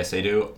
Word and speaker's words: Léa Seydoux: Léa Seydoux, is that how you pronounce her name? – Yeah, Léa Seydoux.